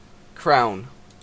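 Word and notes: crown: Canadian

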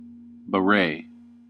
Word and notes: beret: American English

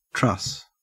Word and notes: truss: Australian English

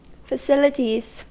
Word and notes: facilities: American English

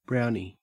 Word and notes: brownie: Australian English

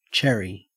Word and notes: cherry: Australian English

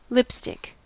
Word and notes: lipstick: American English

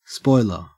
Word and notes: spoiler: Australian English

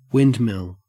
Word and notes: windmill: Australian English

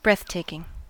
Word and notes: breathtaking: American English

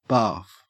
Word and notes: barf: Australian English

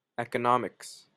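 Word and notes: economics: Canadian

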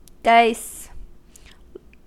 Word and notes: dice: American English

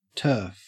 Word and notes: turf: Australian English

